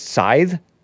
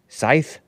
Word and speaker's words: scythe: Oh, it's pronounced scythe? Scythe? Oh, I thought it was Sith. It looks and sounds cooler, I think, saying Sith. But it might be pronounced scythe.